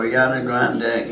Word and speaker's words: Ariana Grande: Ariana Grande. – Yes, there you go, Ariana Grande.